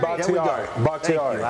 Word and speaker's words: Bakhtiari: How do you pronounce his name? Bakhtiari? – David Bakhtiari.